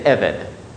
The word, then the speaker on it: Eved: Pronounced, vocalized differently, you would say Eved.